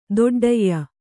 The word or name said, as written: ತಟ್ಠೆಯ